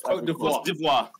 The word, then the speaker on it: Côte d'Ivoire: Have I pronounced it right? How do you pronounce Côte d'Ivoire? – Côte d'Ivoire, yeah.